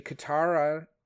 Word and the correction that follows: Katrana: So, Katrana? – Katrana. – Is that how that's pronounced? I think it's Katrana. – Yes, that probably sounds right.